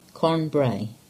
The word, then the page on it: Carn Brea: English